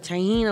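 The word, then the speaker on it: tahini: Pop-Tart and tahine. Am I saying it right? – Tahine. Yeah, okay.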